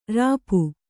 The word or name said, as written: ರೋಪ